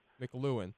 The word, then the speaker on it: McLuhan: McLuhan.